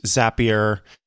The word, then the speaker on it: Zapier: I have used Zapier. Um, and yes, that is how it's pronounced.